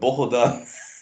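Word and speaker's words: Bohdan: Mit Vornamen Bochodan.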